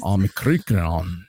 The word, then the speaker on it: Omicron: Omicron. You got to roll your R when you say it.